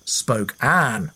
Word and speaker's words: Spokane: Spokane is how I said the city's name. Christy says it's pronounced Spokane, as in Queen Anne."